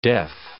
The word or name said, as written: deaf